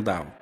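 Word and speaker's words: Daueh: Ahora, realmente, su nombre, él es alemán. D-A-U-E-H. Y yo lo pronuncio Dao.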